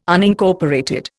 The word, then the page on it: Unincorporated: Indian